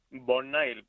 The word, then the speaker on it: Bonnail: Oye, Bonail, no sé si lo he pronunciado bien. – Bonail, perfectamente.